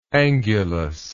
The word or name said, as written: angulous